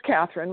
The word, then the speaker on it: Catherine: Okay, my name is Catherine, which is spelled with a C, C-A-T-H-E-R-I-N-E. My last name is Auman, A-U-M-A-N.